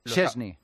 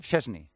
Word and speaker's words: Szczęsny: Szczęsny. Lo estaba diciendo yo bien entonces. – Lo dice él, ¿eh? Szczęsny.